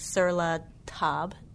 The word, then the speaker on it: Sur La Table: If you page through any kind of magazine like Sur La Table, did I say that right? – Sounds right.